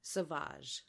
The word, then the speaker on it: sauvage: She owned the stage with what she was referred to as her danse savage, I believe, is how I have it written down phonetically. – Sauvage.